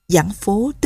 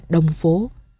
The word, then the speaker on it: Giảng Phố: Vì viết chữ Đông và chữ Giảng gần giống nhau nên nhiều nơi đọc là Đông Phố.